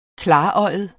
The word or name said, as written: klarøjet